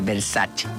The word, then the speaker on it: Versace: Por ejemplo, Versace, cuando la marca Versace es italiana. auto se tiene que pronunciar Versace.